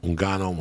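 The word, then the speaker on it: Ngannou: Like, I always say to you, like, oh, I don't mean to pronounce his name wrong, and then Ungayo, right? Like, when he fought Stiopic. – Ungano, when he fought my man, Immigrant Mentality, like a motherfucker.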